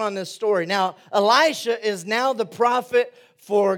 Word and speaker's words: Elisha: Eli- Elisha, Elisha, like Eli.